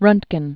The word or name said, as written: rontgen